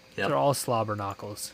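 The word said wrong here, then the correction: slobberknocker: slobber knockers.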